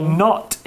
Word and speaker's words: knot: Knot is a word with a silent letter. It's spelled K N O T, but it's pronounced knot.